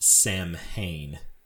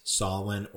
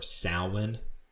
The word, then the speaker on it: Samhain: it looks like it's pronounced Samhain, but I believe it's pronounced Samhain or Samhain, which was a pagan holiday that took place on November 1st.